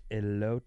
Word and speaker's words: elote: Elote is pronounced elote. – Elote. – It's Spanish for corn.